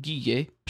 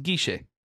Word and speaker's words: Guille: So there's this character named, if I just saw it, I would read it Guille, short for Guillermo. But in the show, his name's pronounced Guiche.